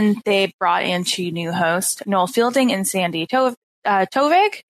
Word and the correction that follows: Toksvig: I believe. Toksvig. – Yeah, so I was trying to figure out how to say her name earlier, and I was like, Toksvig. Yeah, you've got it.